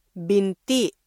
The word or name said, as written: binti